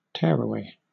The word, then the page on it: tearaway: British English